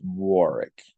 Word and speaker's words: Warwick: It's pronounced Warwick. Warwick Davis.